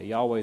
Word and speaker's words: Yahweh: Yahweh is a more accurate pronunciation. Jehovah comes to us because of some German influence where Ys are pronounced as Js and Ws as Vs and so on.